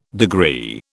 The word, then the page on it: degree: British English